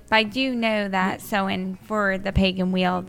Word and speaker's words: Samhain: Also, it's pronounced Samhain. – Okay, Samhain? – Yeah.